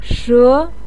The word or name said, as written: she2